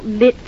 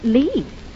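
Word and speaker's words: Lits: I know it's spelt Litz, but over here they pronounce it Lee.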